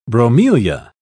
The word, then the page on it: bromelia: British English